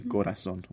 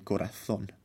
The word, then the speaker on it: corazón: Or if you want to say it with a Spanish accent, the Z is pronounced like a th. It's lisped. So they say mi corazón.